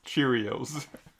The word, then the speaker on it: Cheerios: Euh, euh, j'en mange moins depuis quelques années, mais tu sais, comme l'autre jour, j'ai passé, quand j'avais les churros, je les ai passés en dedans de euh, Marc, ça, se prononce Cheerios.